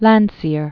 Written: Sir Edwin Henry Landseer

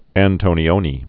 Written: Michelangelo Antonioni

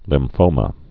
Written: lymphomas

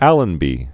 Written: First Viscount Allenby

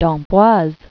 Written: d'Amboise

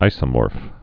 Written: isomorphs'

